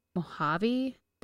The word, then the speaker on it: Mojave: And then a professor in California had contacted the person who wrote this about the Mojave Desert. – Mm-hmm. – I said that right.